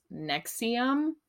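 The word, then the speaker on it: NXIVM: So the first cult we're going to talk about, it's pronounced Nexium. Just like the heartburn medication.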